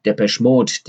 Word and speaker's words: Depeche Mode: Dabei lernt man zum Beispiel, wie Depeche Mode, die ich seit Everything Counts super finde, ihren Bandnamen aussprechen. Nämlich mit einer Betonung auf dem letzten E bei Depeche. Depeche Mode.